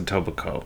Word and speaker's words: Etobicoke: Or I think it's pronounced Etobicoke. I think it's a silent K.